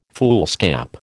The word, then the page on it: foolscap: American English